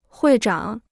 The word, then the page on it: 会长: huì zhǎng